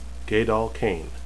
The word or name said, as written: gaidal